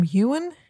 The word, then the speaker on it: Heughan: They have so many different narrators, some of them celebrities like Laura Dern and Sam Heughan, is how his name is pronounced, from the Outlander series.